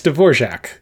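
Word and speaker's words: Dvořák: It's Dvorak.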